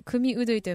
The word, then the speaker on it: 組踊: マックスさん組踊りと書いて組うどいと読みま